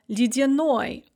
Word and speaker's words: ледяной: For example, ледяной, icy. The stress is on the ending, and so both the ye and the я in the middle are pronounced kind of like e, ледяной.